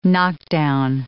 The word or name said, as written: knockdown